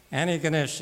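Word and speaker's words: Antigonish: Annie Ganesh, you don't pronounce the T.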